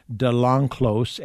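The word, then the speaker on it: de Lenclos: Her name is Nino DeLanclos, and for your listeners, that last name is spelled L-E-N-C-L-O-S.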